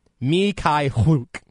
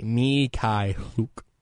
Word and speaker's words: Mykhailiuk: And Fran Fraschilla was making Mykhailiuk 3 words. And that was driving me crazy. Mykhailiuk. There, I found it. I found where I spelled it out phonetically. Mykhailiuk. Just take out the dashes there.